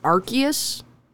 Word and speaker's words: Arceus: It's pronounced Arceus, which is probably the last enunciation of that word that I would have picked in any way, shape, or form.